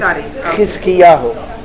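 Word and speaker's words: Hizkiyahu: Oh, then that's in Hebrew, it's Hezekiahu. – Hezekiahu. – With the chet, yeah.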